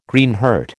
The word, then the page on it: Greenheart: American English